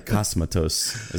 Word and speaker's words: Cosmatos: I think I did, right? Cosmatos. Is that right? – That's all right.